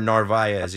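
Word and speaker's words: Narváez: Tomas Nido and you have um, Narvaez. Am I saying his name correctly? Omar Narvaez, yes.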